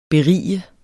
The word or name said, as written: berige